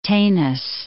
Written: Tanis

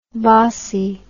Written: bossie

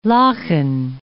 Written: lochan

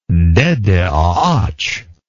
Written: dedeagach

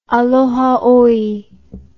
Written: Aloha Oe

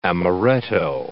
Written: amaretto

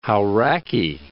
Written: Hauraki Gulf

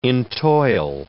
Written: entoil

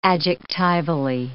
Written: adjectivally

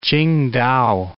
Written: Tsingtao

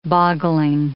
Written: boggling

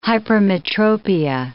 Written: hypermetropia

